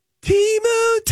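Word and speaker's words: Temu: Now that we have two different pronunciations, Timu, Tamu. Because they gave us a different pronunciation at the Super Bowl.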